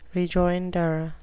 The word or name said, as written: rejoinder